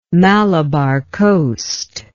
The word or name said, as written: malabar coast